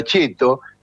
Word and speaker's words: ceto: En italiano, según el lufardismo, donde la palabra ceto, que se pronuncia cheto, significa clase social.